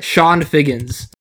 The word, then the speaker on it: Chone Figgins: So my actual pick for number seven is a player whose name I didn't learn how to pronounce until like the last year of his career. Mr. Sean Figgins. Otherwise known as Chone Figgins.